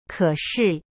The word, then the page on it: 可是: ke shi